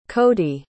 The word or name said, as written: kody